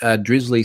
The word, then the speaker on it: Drizzly: Drizzly seems to rhyme with grizzly.